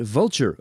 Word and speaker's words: Vultr: And you are at Vulture, of course, which is spelled V-U-L-T-R, and you are headquartered in West Palm Beach, Florida.